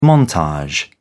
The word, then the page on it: montage: British English